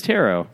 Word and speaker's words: tarot: It does not rhyme with carrot, or else it would be tarot cards. – I've just heard tarot before, but apparently it's tarot.